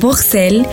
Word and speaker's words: Bruxelles: Hay tres maneras en que sus habitantes pronuncian su nombre. En francés, Bruxelles, en neerlandés, Bruxelles y en alemán, Buchse. Y es que estos tres orígenes son los que conforman esta ciudad y el país.